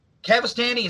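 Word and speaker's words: Cavestany: This one, you know, has guitarist Rob Cavastani.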